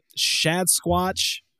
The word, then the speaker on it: Schadsquatch: Adrien, you are better known as Schadsquatch.